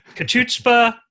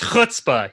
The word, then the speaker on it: chutzpah: And I'm very impressed with your translation, your the way that you pronounced kachutzpah. It's chutzpah. You got to have that ch and then the tzah. And then the puh, that's really, you need all of it.